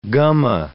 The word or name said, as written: gumma